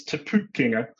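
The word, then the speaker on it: Te Pūkenga: No, the pronunciation wasn't great, but it's all about trying, really. We don't really expect you to have it right first time, but give it a go. It's TEPU Kenya. And it's a long U, and it's the ENG sound is very difficult for people who are not familiar with it to deal with.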